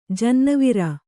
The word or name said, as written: ಜನ್ನಿವಾರ